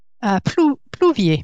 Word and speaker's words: Plouvier: Did I say it right? – Plouvier.